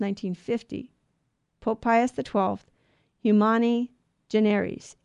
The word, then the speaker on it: generis: But it's generis, because the I in Latin, by the way, is long E. So it's not an I. It doesn't sound like I. It sounds like E. So generis.